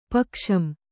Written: ಪಕ್ಷಂ

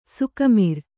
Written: ಸೊಕ್ಕುಮುರಿ

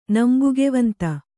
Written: ನಂಬುಗೆವಂತ

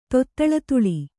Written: ತೊತ್ತಳತುಳಿ

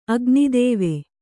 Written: ಅಗ್ನಿದೇವೆ

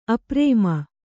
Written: ಅಪ್ರೇಮ

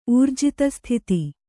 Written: ಊರ್ಜಿತಸ್ಥಿತಿ

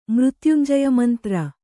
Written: ಮೃತ್ಯುಂಜಯಮಂತ್ರ

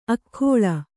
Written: ಅಗ್ಗಳೆ

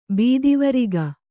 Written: ಬೆತ್ತವರಿಗೆ

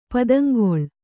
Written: ಪುದುಂಗೊಳ್

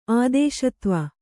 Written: ಆದೇಶತ್ವ

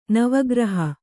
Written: ನವಗ್ರಹ

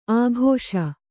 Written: ಆಕಾಶ

